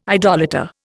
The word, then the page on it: idolater: Indian